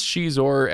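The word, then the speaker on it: Xizor: But Prince Shizor, as you mentioned, he is, and I know you hate that pronunciation, but that is the pronunciation.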